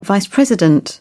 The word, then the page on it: vice president: British English